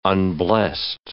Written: unblest

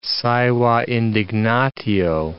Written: saeva indignatio

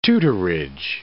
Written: tutorage

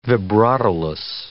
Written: vibratoless

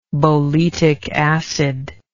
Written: boletic acid

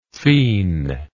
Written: phene

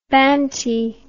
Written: banty

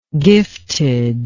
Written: giftedness

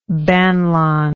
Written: banlon

